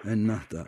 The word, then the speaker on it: Ennahda: Ennahdi Partisi, ismini yanlış telaffuz ediyorum. – Ennahda, evet.